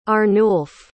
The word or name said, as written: Arnulf